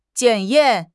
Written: 检验

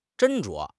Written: 斟酌

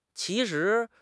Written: qi2 shi2

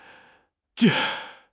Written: 煎

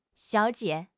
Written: xiao3 jie5

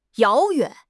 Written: 遥远

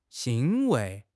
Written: 欣慰

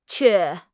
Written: que4

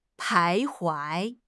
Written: 徘徊

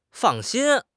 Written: fang4 xin1

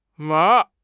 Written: ma2

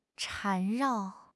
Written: 缠绕

